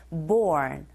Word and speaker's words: born: 자, 본 아니고 R이 들어가 있기 때문에 born.